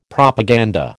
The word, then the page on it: Propaganda: American English